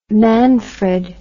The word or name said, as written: manfred